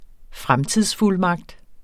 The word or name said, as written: fremtidsfuldmagt